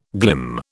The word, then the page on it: glim: British English